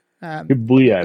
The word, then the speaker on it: Shibuya: The trailer is set in, I'm sorry if I mispronounce this, Quadratum, a location based on Shiba. I said the wrong words wrong. Oh well. – Shibuya, I believe it's called.